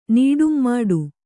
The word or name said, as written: ನೀಡುಂಮಾಡು